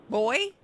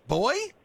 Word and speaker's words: Booy: Boy? – Boy? – Boy. – Boyd, B-O-Y-D? – No, boy, B-O-O-Y, pronounced boy.